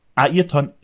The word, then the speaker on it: Ayrton: In Deutschland sagen die Leute so gerne Ayrton Senna. Dieser gute Herr heißt Ayrton Senna, weil der Name Ayrton im brasilianischen oder im portugiesischen Ayrton ausgesprochen wird und nicht Ayrton.